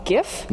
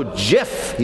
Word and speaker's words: GIF: While accepting a Webby Award this week, the creator of the graphics interchange format said the acronym should be pronounced blank. – GIF? – No, JIF. He settled it forever.